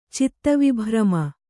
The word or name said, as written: ಚಿತ್ತವಿಭ್ರಮ